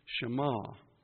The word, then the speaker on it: Shammah: And this name is another compound name, Jehovah Shema. And it's spelled S-H-A-M-M-A-H.